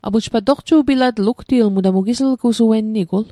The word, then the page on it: toqju'pilatl: Mi'gmaq